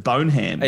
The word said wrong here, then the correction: Bonham: Yeah, I think it's Bonham. I think I said it wrong. Bonham.